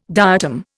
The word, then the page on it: diatom: Indian